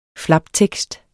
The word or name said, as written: flaptekst